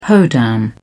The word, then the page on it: hoedown: British English